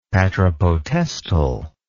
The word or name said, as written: patripotestal